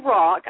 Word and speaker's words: Rach: It's actually Rock. I know that's a strange pronunciation for a last name. Honey, it's okay. And actually, I became Christy Rock in 2002.